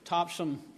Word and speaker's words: Topsham: It's indeed a pleasure to bring to you greetings from Grace Community Church of Topsham, Maine. The spelling is correct in the bulletin. It is spelled with an S-H-A-M, but it is pronounced like it ends in S-U-M.